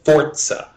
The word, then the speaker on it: Forza: It's Forza. Like pizza.